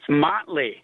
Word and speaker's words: Motley: Hey, is it Motley? Did I pronounce it correctly? – It is. It's Motley, okay.